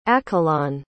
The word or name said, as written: accalon